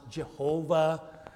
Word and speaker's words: Yahweh: This is the word that we would pronounce maybe, or has been pronounced, Jehovah. In recent times, scholars are telling us perhaps it was really pronounced Yahweh or something like that.